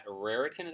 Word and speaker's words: Raritan: Hey, well, we are here at Raritan, is that how you say it? – Yes.